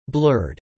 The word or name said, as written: blurred